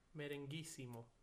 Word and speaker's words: Merenguissimo: Let me see if I can say this correctly. Merenguismo. – Merenguissimo. – Oh, merenguissimo.